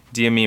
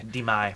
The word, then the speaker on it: Demi: Demi Moore. – Demi.